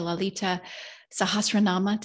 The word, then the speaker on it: Lalita Sahasranama: Living the Lalita Sahasranama. Did I say that right? – Oh, that's perfect.